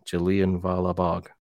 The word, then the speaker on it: Jallianwala Bagh: Did I say that right? Jallianwala Bagh. – Yeah, it sounded good to me.